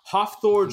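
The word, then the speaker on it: Hafþór: The pronunciation, by the way, of this man's incredibly super Icelandic name is Hofthor Julius Bryson. Or Thor. – That can't be right. – Bjornsson. Sorry, Bjornsson.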